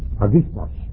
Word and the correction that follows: hagiasmos: I didn't pronounce it exactly right. It's hagiasmos. The emphasis is on a different syllable. It's spelled H-A-G-I-A-S-M-O-S. I left the A out and the emphasis. Hagiasmos.